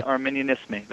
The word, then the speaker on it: Examen Arminianisme: correct my pronunciation, the Examen Arminianisme, is that right? Arminianisme? – Examen, okay. Arminianisme, that's correct.